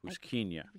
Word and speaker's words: Puschkinia: P-U-C-H. No, P-U-S-C-H-K-E-N-I-A. – Close. P-U-S-C-H-K-I-N-I-A. – K-I-N-I-A. Pushkinia.